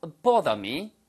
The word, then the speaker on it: bother: 물론 아 발음은요. 바 하는 것은 미국식 발음이고요. It does not. It does not bother me. Bother me. 이렇게 하는 식으로 발음하시면 영국식 발음이 되겠죠. 그래서 bother 아니면 bother 이렇게 발음하시면 바로 되겠습니다. It does not matter.